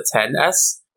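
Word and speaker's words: iPhone XS: Oh, so it's actually they pronounce it 10S. – Oh, because the X. 10S. Because the phone is 10.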